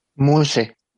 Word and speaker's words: Muche: Entonces, es de la comunidad Muche, ¿lo dije bien? – Muche. – Muche, Ajá. – Oye, aquí, estamos como que muy agringados y es muche y es muche.